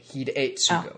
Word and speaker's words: Hidetsugu: Yes, because E is always pronounced as an A because it's annoying like that. – Hidetsugu. – No, hard A. Hidetsugu.